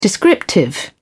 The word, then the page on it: descriptive: British English